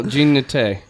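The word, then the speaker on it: Jean Naté: I know I smell Jean Nate.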